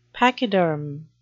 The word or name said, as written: pachyderm